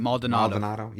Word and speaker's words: Maldonado: Maldonado.